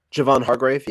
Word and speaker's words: Hargrave: How do you say his? – Javon Hargrave, yeah.